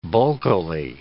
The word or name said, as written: bulkily